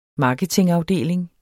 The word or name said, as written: marketingafdeling